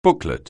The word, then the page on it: booklet: anglais